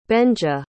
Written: benja

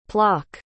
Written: plock

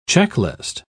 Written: checklist

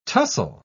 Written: tussle

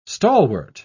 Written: stalwart

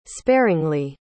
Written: sparringly

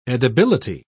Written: edibility

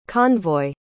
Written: convoy

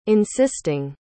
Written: insisting

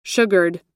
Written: sugared

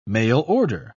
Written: mail-order

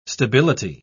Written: stability

